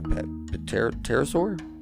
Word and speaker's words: pterosaur: I hope I'm saying that right. Yeah, pterosaur? Yeah, pterosaur species, including large forearms supporting leathery wings. Yeah, pterosaur. I'm almost like a pterodactyl. You know, I pronounced the P before I messed that one up.